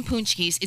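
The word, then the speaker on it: pączki: But they call them poonchkis. It doesn't look like it's spelled.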